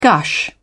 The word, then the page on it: gush: British English